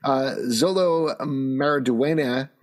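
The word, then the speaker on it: Xolo Maridueña: However, Zolo Maraduena, that's how you pronounce his name.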